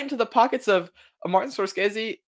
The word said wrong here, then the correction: Scorsese: Why did I say his name like that? Martin Scorsese, Leonardo DiCaprio, Robert De Niro.